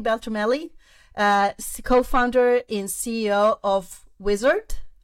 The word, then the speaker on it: Uizard: And amazing that you specify that it's basically pronounced Uizard, but it's spelled with a U.